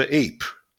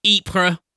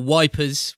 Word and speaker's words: Ypres: He's visited the World War One Yorkshire Trench in Ypres, or Wipers, which is what it was called by the Englishman that couldn't pronounce it back during the First World War.